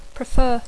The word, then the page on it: prefer: English